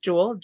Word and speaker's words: Juell: You can just, if you type in Juell Time, it's spelled wrong because my last name is Juell, Juell.